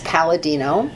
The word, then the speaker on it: Palladino: Last name is Palladino. P is in Peter, A-L-L-A, D is in David, I-N-O, and everything is on the website, and yes, you could find me on Facebook as well.